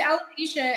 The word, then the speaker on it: alopecia: It's called alopecia, and it's just basically unexplainable hair loss.